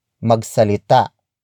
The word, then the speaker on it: magsalita: Kung is spelled as K-U-N-G. In this context, kung means whenever. The next word is magsalita. Magsalita. Magsalita. Magsalita is spelled as M-A-G-S-A-L-I-T-A.